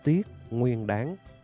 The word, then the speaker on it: Tiết Nguyên Đán: Cho nên đọc đúng theo cái phiên âm phải là tiết nguyên đán.